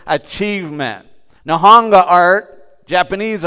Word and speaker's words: Nihonga: And he's purposeful in his art because what he is teaching, in fact, Nihonga art, that's how you say it properly, but Nihonga art is actually Japanese art written to, done to be distinctive from Western art.